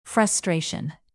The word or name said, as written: frustration